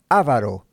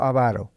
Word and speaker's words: avaro: Y observe cómo estoy pronunciando esa palabra, porque aquí en Estados Unidos, entre los hispanos, yo muchas veces escucho a gente que pronuncia esta palabra avaro. No. No hay acento en la palabra A, en la letra A, no hay acento en la letra A. Es no avaro.